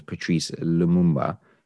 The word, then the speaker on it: Patrice Lumumba: I said his name wrong a moment ago, so I do apologize. His name is Patrice Lumumba, who is the Congolese independence leader.